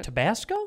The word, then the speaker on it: Tabasco: I always thought they looked like tobacco sauce. Or what? How do you say it? – Tabasco? – Tabasco sauce mixed with beef gravy.